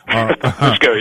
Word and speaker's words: Fusco: Fusco, yes.